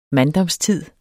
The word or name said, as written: manddomstid